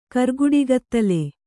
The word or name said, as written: ಕರ್ಗುಡಿಗತ್ತಲೆ